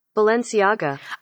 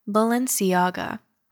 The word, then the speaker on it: Balenciaga: Balenciaga. Pronunciation. – Balenciaga. – I got it right. Balenciaga.